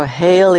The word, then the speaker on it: heel: H-E-E-L. How would you say very good? – Heel goed. – Heel goed. So hail is like hail the chief. Hail to the chief, or it's hailing outside. Hail for very.